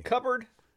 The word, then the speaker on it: cupboard: Cupboard. You say cupboard? – Nobody says cupboard. – Cupboard. – Cupboard. Cupboard. Silent P.